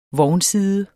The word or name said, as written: vognside